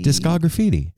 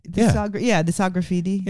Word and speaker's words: Discograffiti: Is that how you say it? – Well, I think it's discography. – Discograffiti. – Discograffiti. – Yeah, like discography. Yeah, yes.